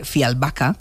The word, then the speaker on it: Fjällbacka: Es verdad que sus novelas parecen guiones, Pues resulta que los crímenes de Fjällbacka, que es como se pronuncia, ella nos lo dijo así la última vez,